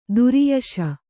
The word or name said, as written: ಧಾರಯಿಸು